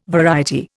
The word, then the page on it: variety: Indian